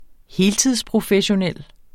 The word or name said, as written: heltidsprofessionel